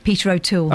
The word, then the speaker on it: Peter O'Toole: Say his name again. – Peter O'Toole. – Okay, you said it right that time.